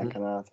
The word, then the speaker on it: Konate: So the the notable one here is how do you pronounce it? Konate? – Yeah, Konate.